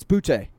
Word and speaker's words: Boutte: How do you say this guy's name? Kayshawn, what is his? Is it Boutte? – Yeah, it's Boutte.